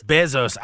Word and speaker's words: Bezos: Bezos. I always mispronounced it when I was there. It's Bezos.